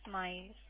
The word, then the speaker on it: Myles: My first name is spelled as M-Y-L-E-S. Last initial is B for Bravo. – M-Y-L-E-S, right? – Yes, Miles.